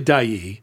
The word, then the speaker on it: Dyea: Well, it's not spelled like you would think. Dai, and this is another word that Chichacos either know or don't. But it's spelled D-Y-E-A. It looks like D-I-E-A. – Diarrhea. – Yeah, D-A-E-A. – Without the R. – But it's actually Dai.